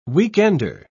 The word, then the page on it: weekender: British English